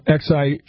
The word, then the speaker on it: Xi: Do a little research there on X-I. – Sure. – So do you think X-I, also known and pronounced in China as Xi, was avoided in deference to the Chinese president, Xi Jinping? – Probably.